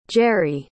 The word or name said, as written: geri